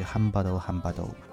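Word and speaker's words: Hambado Hambado (ハンバート ハンバート): 就是来自于 Hambado Hambado，就是 Hambert Hambert 的日语发音，是一个男女组合，呃，老公和老婆一起创作唱歌，然后这首歌的名字叫 Onigakira，中文就是鬼来了。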